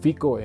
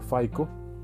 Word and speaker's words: FICO: Eh, en los primeros dos episodios hemos hablado de un sistema que se llama, bueno, yo lo pronuncio más bien en, español, el FICO, en, español sería, en inglés sería FICO, ¿verdad?